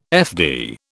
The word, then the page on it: FD: British English